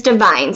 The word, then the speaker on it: Devine: And that's D-E-V-I-N-E. – Correct. So for those of you who haven't realized, my last name is Divine. So I'm Divine on many levels. – Yes, you are. – So it is spelled D-E-V-I-N-E.